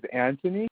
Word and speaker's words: Anthony: My first name is Anthony. A-N-T-H-O-N-Y. And my last name is Castellanos. It is spelled C-A-S-T-E-L-L-A-N-O-S.